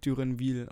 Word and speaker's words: Duranville: So, so, wie ich es verstanden habe, wird der Julien Duranville ausgesprochen.